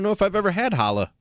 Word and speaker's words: challah: It's C-H-A-L-L. – I know. It's challah. – The C is silent.